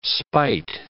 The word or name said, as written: spite